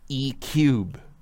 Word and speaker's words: E3: Here's the thing, in the article they they show the thing you know E3, but they say that the organizers say that it should be pronounced E cube. Like they wanted it to no be E cubed.